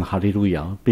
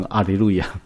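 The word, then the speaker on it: 哈利 路 亚 (הללויה): 那里面经常有一些他们认为最杰出的拉比，而那些拉比在提到这个字的时候都读成哈利路亚，并不读成阿里路亚。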